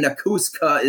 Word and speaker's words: Nakuska: And the second K in Nakuska is silent.